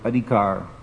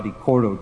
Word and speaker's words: adhikar: And Krishna says in Bhagavad Gita, karmanyevadikaraste, adhikar, this word adhikar, which I always get disturbed that people mispronounce it in the Tulsi prayer, seva adhikara diye koro nija dasi, not seva adhikoro diye. The word there is adhikar.